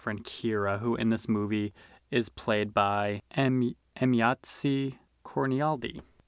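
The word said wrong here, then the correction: Emayatzy Corinealdi: Just hopping on here real quick to say that I looked up the pronunciation because I was like, I couldn't leave it that way. I could not pronounce this poor woman's name right. It's Emyatsi Cornialdi.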